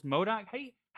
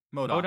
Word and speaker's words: MODOK: And not only that, I'm not sure if you know this, but she's also in that one show, Marvel's MODOK. How do you pronounce that Marvel character? – MODOK. – Like the head? – You're right. MODOK.